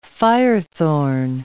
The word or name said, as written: firethorn